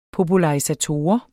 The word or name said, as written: popularisator